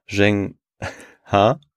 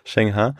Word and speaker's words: Zheng He: Und ich fand, das war eine ganz interessante Geschichte zu dieser Person, dem Seefahrer und dem Eunuchen Zheng He. – Ja. – Hast du richtig ausgesprochen? – Ich glaube schon. – Okay. – Ja, das klang gut. – Zheng He.